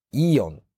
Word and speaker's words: AEON: 皆さんはイオンという会社を聞いたことがありますか ?AEON と書いてイオンです。